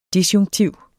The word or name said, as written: DISJUNKTIVT